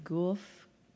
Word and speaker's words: Guelph: Guelph.